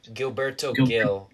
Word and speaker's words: Gilberto Gil: Gilberto Gil, whatever those Brazilians have.